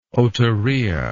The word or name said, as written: otorrhea